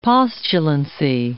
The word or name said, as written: postulancy